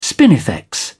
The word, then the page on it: spinifex: British English